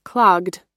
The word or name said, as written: clogged